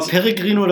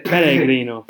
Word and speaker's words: Pellegrino: Ausgesprochen mit diesem... Mit diesem Zungen, Pellegrino. – Pellegrino. Ja, okay, dann Pellegrino.